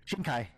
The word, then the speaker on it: Shinkai: As you said, Makoto Shinkai? Did I say that right? – Shinkai.